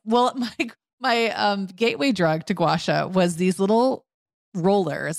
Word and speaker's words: gua sha: So it's two words, gua, G-U-A, sha, S-H-A. But when I hear people say it, it kind of comes out almost like one word, like gua sha.